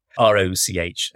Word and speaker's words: Rochambeau: It's called the Rochambeau Club. Rochambeau as in the sort of French way you'd spell it. Rochambeau.